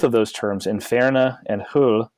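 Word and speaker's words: Hölle: And so, you know, and even in German, the German, and I can't pronounce umlauts because I'm a terrible person, but I think you pronounce it hull. So H-O with an umlaut, L-L-E, just originally meant hill or covered over place.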